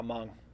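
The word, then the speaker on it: Hmong: Nah, Mung. It's pronounced differently, bro. – The I is pronounced with a U?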